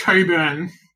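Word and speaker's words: Cockburn: We call it Coburn, but it's actually spelled like C-O-C-K. Burn.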